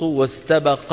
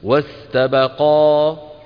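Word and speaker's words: واستبقا: واستبق الباب وكأنه ما في ألف. لو وقفنا عليها شو بنقول؟ واستبقى. إذا وصلنا شو بنقول؟ واستبق الباب.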